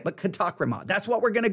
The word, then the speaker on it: katakrima: I'm not saying his way is wrong, but just remember, it's actually pronounced this way. – Strong's G, 2631, katakrima. Katakrima. – Katakrima. He's saying it in a different way. It's perfect. It's perfectly okay. Just a different way of pronouncing it.